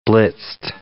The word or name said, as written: blitzed